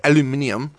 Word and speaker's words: aluminium: It had a aluminum, or as the Brits say, aluminium.